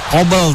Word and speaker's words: Kobel: Kobel ya, Kobel ya, spelling ya. – E-nya agak beda nih.